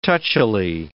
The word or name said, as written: touchily